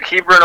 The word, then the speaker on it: Hebron: Now, National Trail is in Hebron? How do you pronounce that? – Yep, Hebron, Ohio, just east of Columbus, Ohio.